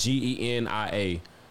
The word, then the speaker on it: Genia: Pardon me if I'm pronouncing the name wrong, but it's G E N I A, Genia.